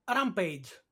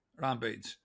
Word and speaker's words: Rampage: Rampage. Rampage.